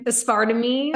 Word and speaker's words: aspartame: How do you say aspartame? – Aspartame. – Aspartame. Yes.